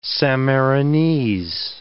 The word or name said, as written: Sammarinese